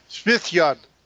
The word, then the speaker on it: Svithjod: That's Svithjad. – Svithjad, okay. – Yeah, the J is pronounced as a Y. – Right, okay. – Same as it is in the Hebrew.